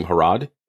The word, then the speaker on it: Harrod: It's at my first name, Sylvania, like Pennsylvania without the pen, and last name, Harrod. Like, it's like Harrods of London, but it's pronounced Harrod.